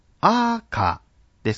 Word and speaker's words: 赤: 先ほどアクセントの例の中で示しました通り、赤というのはアーカーですから頭高型ですね。